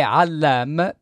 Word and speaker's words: Allam: Toglimi una curiosità, Magdi, Allam o Allam? Perché io sento varie versioni. – In arabo è Allam, per cui dovrebbe essere Allam.